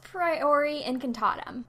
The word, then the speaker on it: Priori Incantatum: And then they're dueling, and their wands, you know, because they're twins, sort of connect, and they have the whole Priori Incantatum. How do you say that? – I think it's Priori Incantatum. – Priori Incantatum. Okay, I was close.